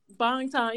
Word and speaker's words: Bangtan: Bangtan, yeah, it's Bangtan, but I always say like I always sound weird.